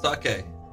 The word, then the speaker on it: sake: Sake.